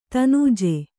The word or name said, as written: ದನುಜೆ